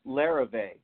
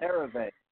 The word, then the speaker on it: Larrivee: Well, first I had to take 3 years of French so I could pronounce Larrivee. – Larrivee. See, I can't even pronounce it. – Larrivee. Once you can say it, then they'll endorse you.